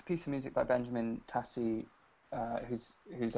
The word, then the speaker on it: Tassie: Yes, Benjamin Tassie.